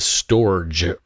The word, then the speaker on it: Storj: Last year, you won the hackathon, first annual Texas Bitcoin Conference hackathon, and you won with Storage. For listeners who are not familiar, that is spelled Storj.